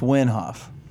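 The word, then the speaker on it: Wim Hof: And I think I said Minhoff out of my dyslexia. Oh, you said his name wrong? – Yeah, it's Winhoff.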